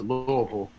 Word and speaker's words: Louisville: And I also want to mention that with Louisville, and that's how you pronounce it, Louisville, I'm seven generations Kentucky on my father's side.